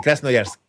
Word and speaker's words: Krasnoyarsk: Sí, lo dije bien, eso sí, Krasnoyarsk.